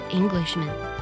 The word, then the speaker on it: Englishmen: 不过呢有一点需要特别记住，它的单复数形式发音是一样的，单数形式读作 Englishman，复数形式呢虽然将 A 变作 E 了，也读作 Englishman。